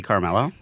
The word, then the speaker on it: Carmello: We already mentioned Judy Kuhn, Anthony Rapp, Carmella. She was married to Tony on The Soprano. – This is going great. – Carmella Soprano. No, how do you say her name? – Carolee Carmello. – Thank you.